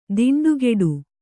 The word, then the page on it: ದಿಂಡುಗೆಡು: diṇḍugeḍu